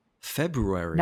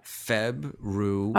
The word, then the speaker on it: February: But okay, so how do you say the name of this month as we're going to finish it out? – February. – Nope, that's wrong. How do you pronounce it? – February. – Oh, February. Right. – Yeah, February. – Right, but nobody does it that way. They go February. – No, everybody's February. Absolutely.